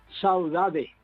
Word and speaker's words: saudade: Saudade. – Saudade, sí. Una hermosa guaraní. – En portugués se pronuncia saudade.